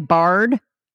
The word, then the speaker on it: Bard: Am I even pronouncing it right? Bard? – Yes. Yes.